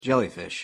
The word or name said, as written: jellyfish